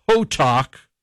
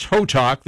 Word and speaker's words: Hotteok: It's inspired by popular Korean street food called, it's pronounced Hotok. It's spelled Hotteok, but it's pronounced Hotok.